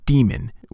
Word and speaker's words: daemon: But I would say that in ordinary spoken English, it is just pronounced demon, with the AE sound being E, like encyclopedia or archaeology.